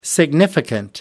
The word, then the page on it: significant: American English